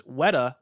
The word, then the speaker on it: Weta: Kebab says Weta is pronounced Weta, like wet, because I said Weta.